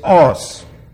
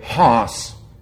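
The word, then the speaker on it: os (ὅς): It's actually a rough breathing, but it's not pronounced in modern Greek. It's os. Bible school Greek would say hos. But that's Western for what you ride. Os is the way to say it correctly.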